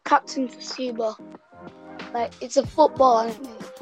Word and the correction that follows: Tsubasa: Captain Tsuba. It's Captain Tsubasa or whatever.